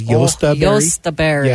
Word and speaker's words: Jostaberry: And I think if you tried to pronounce that correctly, it was a European hybrid, and it's Jostaberry. Yeah, Josta.